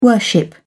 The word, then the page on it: worship: British English